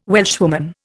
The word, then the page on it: Welshwoman: Indian